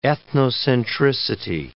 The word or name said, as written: ethnocentricity